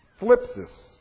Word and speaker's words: thlipsis: The Greek word that he used here was, I'll spell it first, T-H-L-I-P-S-I-S. Phlipsis is what I would call it.